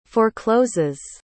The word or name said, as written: forecloses